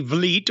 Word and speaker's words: Vliet: It is confusingly spelled. V-L-I-E-T. I guess that would be Vliet or Vliet. Vliet.